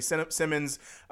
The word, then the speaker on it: Simons: got a guy like Anthony Simons. I don't know why they pronounce it.